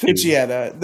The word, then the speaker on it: Poochyena: It's Poochyena. No, it's you're Poochyena. Wrong. It's Poochyena.